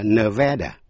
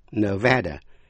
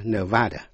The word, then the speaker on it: Nevada: Thí dụ, tên tiểu bang Nevada đọc là Nevada, Nevada, chứ không phải là Nevada.